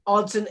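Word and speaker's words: Odsonne: Odson Edouard.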